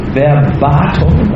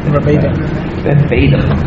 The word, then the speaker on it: verbatim: In the Bhagavad Gita, Lord Krishna gives a very well-known shloka, and this, to my knowledge, is the only verse which appears verbatim or verbatim. Verbatim in American pronunciation. It's actually a Latin word and it should be pronounced verbatim.